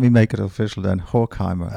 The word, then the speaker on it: Horkheimer: So I'm thinking also, so there's that recently published conversation between Adorno and Horkheimer. Am I saying his name right? – Yes.